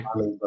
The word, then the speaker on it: Simons: Yeah, and I mean, like, look, the surprising thing for me this year has been the play of Anthony, you know, Simmons, right? Simons, how do I say it? – Simons, I think, yeah.